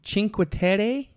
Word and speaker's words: Cinque Terre: And then we went up to Cinque Terre.